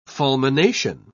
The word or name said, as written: fulmination